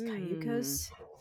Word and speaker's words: Cayucos: I went through and I'm like, okay, I see that it's Spanish for canoe. So it's Cayucos.